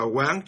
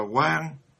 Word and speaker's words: Quán: Cái chữ quán ở đây đó là, các vị phải biết rằng nó là đọc thì nó cùng nghĩa với từ quan. Mà tại sao mình không đọc là quan mà mình lại đọc là quán?